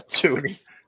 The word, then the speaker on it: toonie: A toonie.